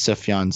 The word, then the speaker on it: Sufjan: For some reason, like, I want to say Sufjan, but I think it's Sufjan Stevens. – It's Sufjan Stevens. – It's Sufjan. Okay. So I was right originally.